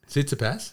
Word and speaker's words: Tsitsipas: He took out Tsitsipas in the last round. – Mate, you did well to deliver that one. Well, do that again. – Tsitsipas.